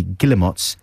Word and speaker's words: Guillemots: And before Bedouin Sound Clash, you heard, of course, the Guillemots. Some people say Guillemot. I say Guillemots just to avoid. – I say Guillemot, yes. – Guillemot. And that's Trains to Brazil. Guillemots, yeah. It says underneath, pronounced Guillemots.